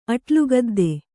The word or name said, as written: ಅಟ್ಲುಗದ್ದೆ